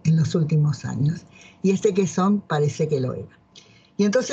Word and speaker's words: Quezón: En Filipinas pasa que Filipinas tenía un presidente que tenía un apellido que me causa un poco de gracia porque, bueno, en España sería Quezón. Para nosotros que no pronunciamos la Z sería Quezón.